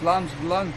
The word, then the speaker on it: Vlaams Belang: I don't know, how do you pronounce that? – Belang, Vlaams Belang.